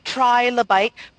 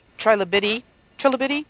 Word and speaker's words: trilobite: And I know that it is correctly pronounced trilobite because I heard David Attenborough reading from his latest book, and he said trilobite. So my attempt at trying to change it to trilobity, whatever I was trying to change it to, was incorrect.